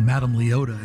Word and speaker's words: Madame Leota: Little did I know that she was being Madame Leota and telling me my future.